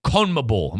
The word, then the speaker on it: CONMEBOL: it was a World Cup qualification for CONMEBOL.